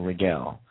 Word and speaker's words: Raguel: This is also Archangel Raguel. That's spelled R-A-G-U-E-L. Archangel Raguel.